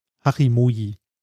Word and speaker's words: Hachimoji: also äh, äh, H-A-C-H-I-M-O-J-I. Hachimoji. Ähm, das kommt aus dem Japanischen, deshalb spricht man es wahrscheinlich komplett anders aus. Hachimochi, Moch, äh, wie auch immer, keine Ahnung. Äh, auf jeden Fall steht das Ganze äh, wörtlich übersetzt ähm, für acht Buchstaben.